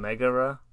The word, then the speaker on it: Megara: I'm sorry, I was just reading some Megara, mm-hmm. – is how it's pronounced, and she is a real Greek mythological character.